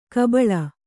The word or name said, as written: ಗಬ್ಬಲು